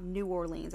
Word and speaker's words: New Orleans: And I have never been to New Orleans, but I have been told that you're supposed to say it like all one word. It's not New Orleans, it's New Orleans. – New Orleans. – Or Nolans, yeah.